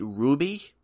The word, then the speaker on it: RWBY: It's actually pronounced Ruby, and it's issue number one of seven.